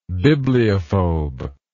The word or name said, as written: bibliophobe